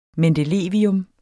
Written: mendelevium

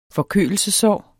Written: forkølelsessår

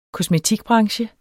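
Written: kreaturhandler

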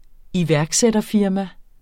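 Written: iværksætterfirma

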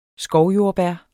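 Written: SKOVJORDBÆR

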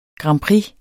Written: grand

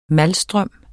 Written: malstrøm

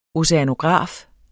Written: oceanograf

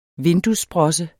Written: vinduessprosse

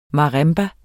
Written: marimba

